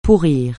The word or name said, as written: pourrir